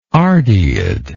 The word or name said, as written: ardeid